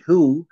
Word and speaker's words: Hugh: And I think, even though it isn't spelled H-U-G-H and you pronounce it who, without the G-H, Mike, but I really believe that it has some reference to Hugh John Fleming.